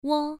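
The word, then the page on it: wo: tone 1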